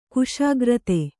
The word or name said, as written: ಖುಷ್ಕರೀದಿ